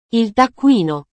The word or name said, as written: il taccuino